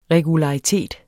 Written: regularitet

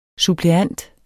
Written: suppleant

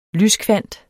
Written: lyskvant

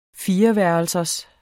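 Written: fireværelsers

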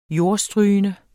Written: jordstrygende

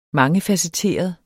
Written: mangefacetteret

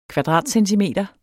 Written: KVADRATCENTIMETER

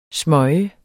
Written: smøge

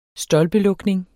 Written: stolpelukning

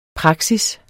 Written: praksis

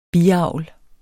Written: BIAVL

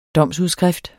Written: domsudskrift

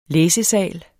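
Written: læsesal

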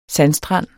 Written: sandstrand

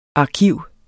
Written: arkiv

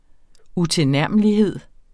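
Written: UTILNÆRMELIGHED

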